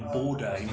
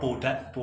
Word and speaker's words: Bourdais: It's Sebastian Bourdais, not Bourdais, or whatever."